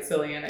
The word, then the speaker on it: Cillian: But I did think it was artful as far as his choices, in particular the way that Cillian Murphy, am I pronouncing that right, Cillian? – I believe so, yeah.